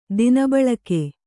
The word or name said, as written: ದಿನಬಳಕೆ